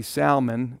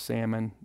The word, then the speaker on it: Salmon: And then you see Salmon, which that is how you pronounce it. It's not pronounced Salmon. You actually pronounce it with the L.